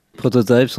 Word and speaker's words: Prototype: Comment on doit le prononcer Prototype, en anglais.